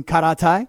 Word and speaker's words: karate: Is that why you were taking karate? – No, I had started karate very much earlier than that, yes. – Mm. See the way I didn't anglicize that? – You didn't. You actually said it properly.